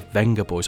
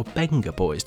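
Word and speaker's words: Vengaboys: We open tonight with the Venga Boys, or Benga Boys, depending on how you pronounce it. But I will pronounce it Venga Boys because I am aggressively British.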